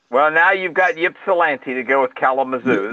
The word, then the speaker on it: Ypsilanti: It's Ypsilanti. Who spells anything with a YPS as the first three letters of a word? – You've never heard of Ypsilanti, Michigan? – Ypsilanti, Michigan.